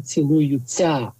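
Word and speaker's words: цілуюся: Тому хоч ми і пишемо «цілую-ся», але вимовляємо «цілую-ця».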